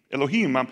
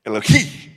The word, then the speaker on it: Elohim: Elohim, if you really say it right, you would get spit on the microphone. Elohim, you know, Elohim.